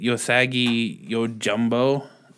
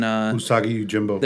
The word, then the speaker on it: Usagi Yojimbo: Usagi Yojimbo. The fucking... – Usagi Yojimbo.